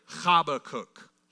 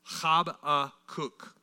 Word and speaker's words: Habakkuk: You can just read that word and know you're never going to get that one right, pronouncing it right. It's actually Habakkuk. Habakkuk.